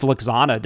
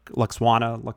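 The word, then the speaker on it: Lwaxana: Luxwana. I always say that wrong. – The hardest name in all of Star Trek. Luxana. Yeah. – Luxwakwana. – It's a half silent W. – Yeah, just says half of it.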